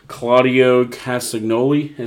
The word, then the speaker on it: Claudio Castagnoli: Claudio Castagnoli, is that what yeah, it is? Oh, did I say yeah, it right? Yeah.